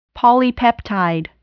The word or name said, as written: polypeptide